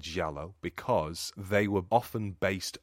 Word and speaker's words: giallo: The original is the Dario Argento 1977 classic, which is an Italian giallo, which I actually said that wrong. I said that with a Portuguese accent. – Giallo. – So you say giallo.